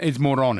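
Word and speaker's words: Morón: The town on the Caribbean coast, it's M-O-R-O with an accent over N. How do you pronounce that town? – Moron, is it? – Yes.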